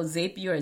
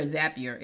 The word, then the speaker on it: Zapier: Potato, potato, tomato, tomato, Zapier or Zapier. It depends on how you pronounce it.